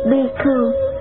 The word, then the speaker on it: Bhikkhu: Tỳ Khưu là tiếng Ấn Độ, nguyên âm đọc là Bhikkhu, có hàm ba nghĩa.